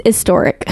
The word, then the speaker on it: historic: The H is silent. It's historic.